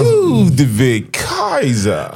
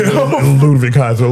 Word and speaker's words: Ludwig Kaiser: Okay, speaking of heel antics, Lou with Kazi, you know, he on his little... – Hold on. – He on his... – He ain't say it right, bro.